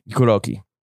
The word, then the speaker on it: Kuroki: Is that how Kuroki? – Kuroki.